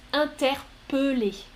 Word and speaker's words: interpeller: La règle en français, normalement, quand il y a deux L, le E se prononce E. On est d'accord. Mais pas avec ce verbe. Ce verbe se prononce Interpellé Interpellé.